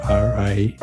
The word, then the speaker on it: Rijon: And we had a quiz before we started because I asked him how I should pronounce his name, and I failed it 3 times in a row. But I think it's a musical name, Rijon. Ericson is a special guy, and I'm glad to have met him, and I hope to meet him soon again.